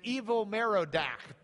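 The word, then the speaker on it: Evil-Merodach: Nebuchadnezzar dies in 562, and evil Merodach becomes king. Evil is not an adjective. That's his name. That was his name in Hebrew pronunciation.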